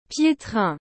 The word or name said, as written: pietrain